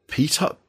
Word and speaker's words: petard: So you can use siege claws, there's storm lasers, conversion beamers, like rapid fire butcher cannon, and the soul burner petard, I think it's called. Ooh. I never know how to pronounce that. – I think it's petard, but yeah.